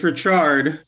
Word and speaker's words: Richarde: Am I saying your name correctly? Because I see the E in the end. – Yeah, so it's and I'm always thinking that to say. – It's Richarde.